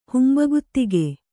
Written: ಹುಂಬಗುತಿಗೆ

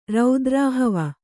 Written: ರೌದ್ರಾಹವ

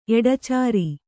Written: ಎಡಚಾರಿ

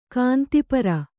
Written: ಕಾಂತಿಪರ

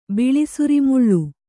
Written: ಬಿಳಿಸುರಿಮುಳ್ಳು

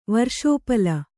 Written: ವರ್ಷೋಪಲ

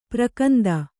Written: ಪ್ರಕಂದ

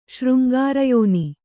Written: ಶೃಂಗಾರಯೋನಿ

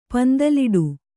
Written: ಪಂದಲಿಡು

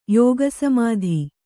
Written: ಯೋಗಸಮಾಧಿ

